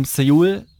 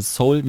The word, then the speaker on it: Seoul: Da habe ich aufgeschrieben Seoul, beziehungsweise Seoul, wie man das ja ausspricht. Ich bleibe bei Seoul. Ich mag, also ich werde es immer Seoul aussprechen.